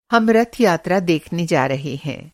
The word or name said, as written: रथ यात्रा